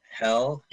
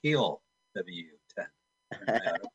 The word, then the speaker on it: Hell: Hell? – Hell, H-E-L-L, space, W-10, yes. W-10. – It's Heel W-10. – That is the German pronunciation, yes. H-E-L-L space W-10, 1983.